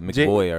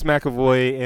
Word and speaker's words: McAvoy: I was going to say like McBoy or whatever. – Well, I think it's pronounced McAvoy, but I butcher names all the time anyway.